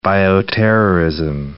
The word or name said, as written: bioterrorism